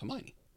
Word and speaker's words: Hermione: I said to one of my relatives, how do you say this name? She goes, it's Hermione. – Okay. What was you saying, like Hermione or? – Hermione. I wouldn't say that properly.